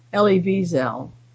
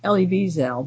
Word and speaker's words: Elie Wiesel: They will be joined by a number of Holocaust survivors from North America, headed by Nobel laureate and survivor Elie Wiesel. His name should actually be pronounced Elie Wiesel. Elie Wiesel, but not with a big accent on the last syllable, which people tend to put on it.